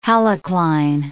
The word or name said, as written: halocline